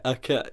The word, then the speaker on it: Arquette: Patricia Arquette, is it? Is that how you pronounce it? Arquette, yeah.